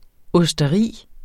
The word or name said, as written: OSTERIERNES